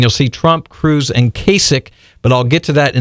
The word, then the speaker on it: Kasich: And so get out a pen and paper or listen, because I'm going to give you the latest on Trump, Cruz, and Kasich. Yes, his name is pronounced Kasich, like someone's sick, okay? It's not Kasich.